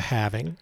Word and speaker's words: halving: I mean, every 4 years we got the halving coming up in May. – Yeah. Ooh, what do you think about that? The halvening or the having? How do you say it? – It's a halving.